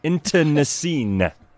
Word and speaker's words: internecine: Internecine. – Internecine. – Internecine. Internecine. – It's like into the scene, but fast. Into the scene. – Did I spell that right? Intern, N-E-C-E-N-E.